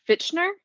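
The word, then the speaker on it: Fitchner: Slash, I can never say it right, Fichtner? – Oh, yeah.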